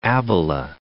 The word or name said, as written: Abyla